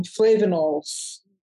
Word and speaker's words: flavanols: On um, particular, there are plant derived um, flavanols.